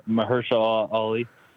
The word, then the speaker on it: Mahershala Ali: yeah, I'm really hoping uh, Chicken Masala Ali makes that uh, remake. I want to see more Blade. – Oh, M- Mahershala Ali.